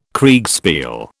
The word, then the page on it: Kriegspiel: British English